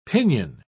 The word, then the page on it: pinyon: British English